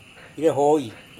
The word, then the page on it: irehoi: Serewen